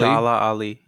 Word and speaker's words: Mahershala Ali: Mahershala Ali. – Mahershala Ali.